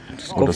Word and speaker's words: Skövde: Genau, und jetzt kleinen Schlenker nach nach äh, Skowde oder Skövde, je nachdem wie es ausgesprochen wird. – Skowde, Skowde, Genau.